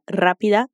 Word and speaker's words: rápida: Pero significa mais en portugués y la palabra rápida se pronuncia con el sonido semejante al de la R de arara.